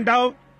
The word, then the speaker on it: Daugh: நான் உள்ளே சென்று அந்த பிராணவாயு கூடாரத்துக்குள் என் கரத்தை வைத்து, சகோதரன் டாவ் என்னை கேட்கிறதா என்றேன். அவர் மேல் நோக்கி என்னை பார்த்தார். உண்மையிலேயே அவருடைய பெயர் ஒரு ஜெர்மானிய பெயர், DAUGH. நான் அதை டவ் என்று உச்சரிப்பேன், புரிகின்றதா?